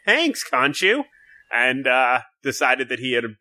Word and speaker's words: Khonshu: Right before he passed out, he was like, and crawled up to a shrine, and it was a shrine dedicated to either Khonshu or Honshu. I don't know if the K is silent. Sometimes it is, sometimes it's not.